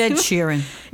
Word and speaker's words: Sheeran: Yes, it's Ed Sheeran.